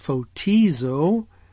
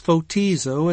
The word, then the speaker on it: photizo: It is a Greek word that would be pronounced photizo. I suppose it's related to photo, where we get our English photograph from, but it's photizo.